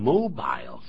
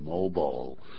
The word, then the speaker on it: mobile: 这个 mobile phone 也有念成 mobile phone. – 对，那是英式腔。 – 啊，两个人说法都可以，但美式英语是念成 mobile，所以再把这个我们例子好不好？